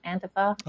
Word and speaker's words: Antifa: They don't know anything about, I can't even pronounce it, Antifa? – Antifa.